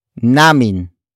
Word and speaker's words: namin: The answer is introduced by the next word, namin.